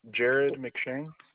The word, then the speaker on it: Jared McShane: Jared McShane. – Can you spell that for me? – J-A-R-E-D M-C-S-H-A-N-E.